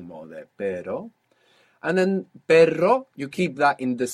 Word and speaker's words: perro: So initial R, or two R's together inside the word, is going to pronounce this double R. So the word for but in Spanish is pero. – Pero. – P-E-R-O. And the word for dog is perro. P-E-R-R-O.